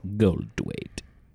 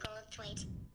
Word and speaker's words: Goldthwait: Goldthwait.